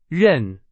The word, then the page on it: 任: Chinese